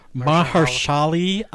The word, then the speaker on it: Mahershala: Mahershala Ali. – Mahershala. – Mahershala. – Yeah. – M-A-H-E-R-S-H-A-L-A.